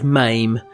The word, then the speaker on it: Mame: Now, I had to look up how to pronounce this, and it didn't seem that conclusive, but I'm just going to go with MAME. It's spelled M-A-M-E.